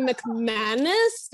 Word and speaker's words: McManus: McManus. You make it sound like anus, like it's definitely McManus, but you're like McAnus, and I'm kind of all right with it.